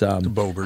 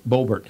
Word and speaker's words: Boebert: Green and Bobbert, it's not Bobbert, it's Boebert. – How do you say that name? Boebert. – Yep.